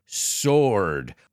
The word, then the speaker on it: sword: And if you noticed, I didn't pronounce the W. I don't say sword, I said sword.